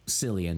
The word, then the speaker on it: Cillian: So Cillian Murphy is how I've heard it pronounced. – Cillian.